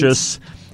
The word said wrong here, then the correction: prescient: and... – it's prescient. – It's prescient. – It is. It's prescient.